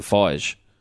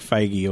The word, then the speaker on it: Feige: Do you say Feige or Feige? How do you pronounce his name? I always say Kevin Feige. – No, it's like Feige or something.